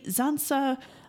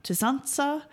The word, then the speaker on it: Tzantza: Now, they say Zansa, which is a mispronunciation of Tzantza, which is the Jivaroan word for the shrunken head.